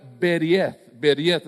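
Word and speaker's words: beriet (ברית): Y esa palabra que Moisés, cuando él usaba esta palabra en estos primeros cinco libros de la Biblia, él usó la palabra beriet, así más o menos se pronuncia del hebreo.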